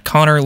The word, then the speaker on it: Connor: Yeah, Connor Lee Music.